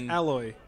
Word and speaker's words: Aloy: It's pronounced Aloy.